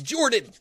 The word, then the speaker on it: Jorphdan: My name is Jordan, with a silent ph in the middle, and I am joined always by my wonderful co-host Sir Lucien over at Sir Lucien Gaming.